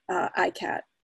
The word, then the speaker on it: ICCAT: So this is ICCAT, which is pronounced, and I'll use this throughout my introduction, is pronounced ICAT.